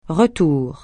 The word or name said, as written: retour